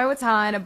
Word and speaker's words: Vatan: It just goes by Watan, but it's spelled V-A-T-A-N. So it's spelled like Vatan, by the way.